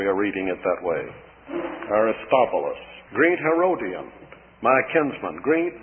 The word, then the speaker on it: Aristobulus: Sometimes this is given the pronunciation of Aristobulus. In fact, the version I'm reading has it that way.